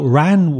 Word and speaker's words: Wren: Now, Ran, when I say that, you probably think of spelling R-A-N, but in fact, it's the bird, W-R-E-N, Wren. So in Ireland, you typically find it, you know, the broad pronunciation, the Ran.